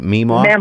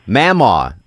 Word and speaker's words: Mamaw: So Meemaw, right? I'm pronouncing it right? Meemaw? – Mamaw. – Mammal was your biggest mammal. – Spell it. – M-A-M-A-W. – Mammal. – Yeah, you got a little bit of country draw at the end of it.